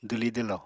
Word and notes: dwli-dwlo: apatani

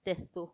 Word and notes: tedhu: Macuiltianguis

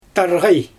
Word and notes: tarɣaj: Xyzyl

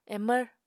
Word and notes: emvr: Apatani

